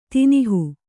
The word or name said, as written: ತಿನಿಹು